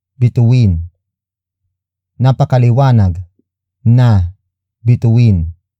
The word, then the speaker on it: bituin: The next word is bituin. Bituin. Bituin. Bituin is spelled as B-I-T-U-I-N.